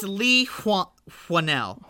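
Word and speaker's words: Leigh Whannell: It's Lee Wannell.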